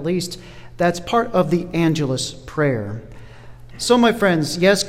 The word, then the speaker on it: Angelus: It's spelled like the word angel with a little difference at the end, A-N-G-E-L-U-S. That's again, A-N-G-E-L-U-S, pronounced Angelus. Should be your first hit on your Google search.